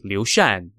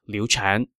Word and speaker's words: Liu Shan: Oh, and by the way, I realized that I have been pronouncing Liu Shan's name incorrectly as Liu Chan. The character for his given name has two pronunciations, Chan or Shan, and in this case, it should be Liu Shan, not Liu Chan.